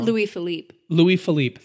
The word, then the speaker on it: Louis Philippe: Now, Louis Philippe, I'm probably saying this wrong. – Louis Philippe, thank you. – Yeah. – You know your French better than me.